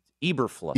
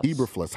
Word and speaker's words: Eberflus: How well do Brian Poles and Coach Eberflus, is that how you say it? – I think it's Eberflus. – Eberflus.